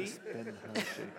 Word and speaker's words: Crispin Hershey: And the Crispin Hershey. Yeah. Four syllables.